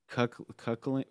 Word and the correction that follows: cuckolding: What is it? Cuck? – Cuckolding. – Cuckolding. There you go.